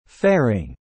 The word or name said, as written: faring